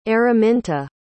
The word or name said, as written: Araminta